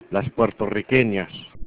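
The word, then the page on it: puertorriqueñas: spanish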